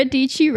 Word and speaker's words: Dietschy: Sara Dietschy rhymes with peachy.